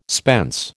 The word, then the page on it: spence: American English